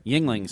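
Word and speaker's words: Yuengling: So, yeah, that's the Yingling – how do you say it? – Yingling Center.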